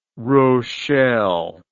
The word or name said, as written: Rochelle